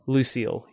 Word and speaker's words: Lucille: And if you were lucky enough to get the next one, Lucille? Is that how you pronounce it? – Lucille, yes.